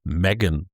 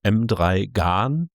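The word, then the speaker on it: M3GAN: Und zwar habe ich mich auf den Film Megan gestürzt. Eigentlich, wenn ich es vorlesen würde, wäre es M3 Gan, weil so schreibt man das nun mal.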